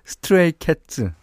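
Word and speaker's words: Stray Cats: 스트레이 캣 스트라트 들으셨어요. 아. 이게 스트라트 어 아니, 스테이크 왜 이렇게 발음이 안 되죠? 스트레이 캣츠.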